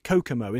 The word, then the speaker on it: Kokomo: and it's Kokomo, isn't it? That's how you pronounce it.